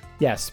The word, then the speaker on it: Ys: Yes, properly pronounced E A S E, like the word ease, like when I understand.